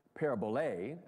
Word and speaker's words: parabole: Parable is comes from the Greek word for a parabole, or even phonetically, it's parabole, actually.